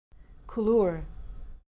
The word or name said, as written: coulure